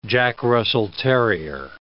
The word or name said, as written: Jack Russell terrier